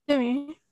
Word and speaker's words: Demi: Demi.